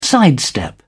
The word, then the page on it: sidestep: British English